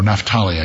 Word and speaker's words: Naphtali: It's associated with Capricorn and also the tribe of Naphtali. Or Naphtali, I guess is a better way to pronounce it.